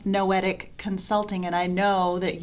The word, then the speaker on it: Noetic: So noetic rhymes with poetic. Sometimes people have trouble pronouncing it.